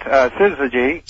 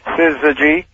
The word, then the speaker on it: Syzygy: Oh, it sounds like there's an R in there. – Well, it shouldn't. Syzygy. – Syzygy. – Unless you have from New York, you might say a little R. But actually, I put it phonetically at the on the header of my newsletter so that people, first time through, they often pick it up.